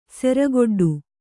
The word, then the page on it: ಸೆರಗೊಡ್ಡು: siragoḍḍu